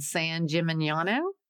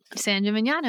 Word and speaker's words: San Gimignano: And then a little town I can never pronounce called San Gimignano. – San Gimignano, that's right.